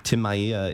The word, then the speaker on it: Tim Maia: Um, Tim Maia is, did I pronounce it correctly? Mm-hmm. – Yeah, okay.